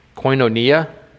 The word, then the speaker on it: koinonia: That word comes from the Greek word koinonia.